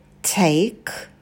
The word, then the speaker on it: take: Take, not, и на «к» добавляйте придыхание.